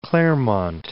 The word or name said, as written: Claremont